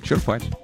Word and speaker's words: черпать: Черпать. Ну, почему нет? На самом деле, вот словари все почти указывают совсем другое. Правильное ударение на первый слог. Этот вариант зафиксирован в большинстве нормативных словарей. Черпать на «е».